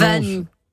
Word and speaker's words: Wagny: C'est comme ça qu'on prononce? – Wagny. Oui, Wagny, comme dit Valérie.